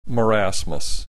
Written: marasmus

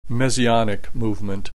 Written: messianic movement

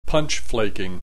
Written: punch flaking

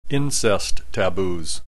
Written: incest taboos

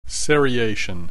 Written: seriation

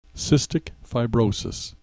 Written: cystic fibrosis

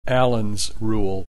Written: Allen's Rule

